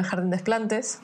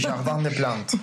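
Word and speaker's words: Jardin des Plantes: Sí, no se dice así. – Es Jardín de las Plantas o de Plantes. – Sí, Jardín de Plantes.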